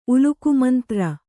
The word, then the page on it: ಉಲುಕುಮಂತ್ರ: ulukumantra